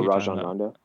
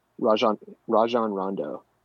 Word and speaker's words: Rajon Rondo: Roland Rojo. – Rajon Rondo. – Rajon Rondo. What'd you say? Rondo. – He's got those tiny little dreads. – What's his name? Say his name again. – Rajon Rondo.